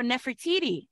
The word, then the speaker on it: Nefertiti: So Nefertiti,